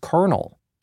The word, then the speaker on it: Colonel: Derek told me it was pronounced Colonel. It's Colonel, bah, not Colonel.